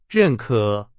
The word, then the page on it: 认可: Chinese